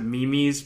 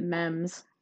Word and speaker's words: meme: I think the IRS probably pronounce it memes, because they just, of course they do. – But, well, Mike pronounces it mems, so...